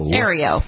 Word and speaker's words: Aereo: There's a new technology called Aero, A-E-R-E-O. – Aero.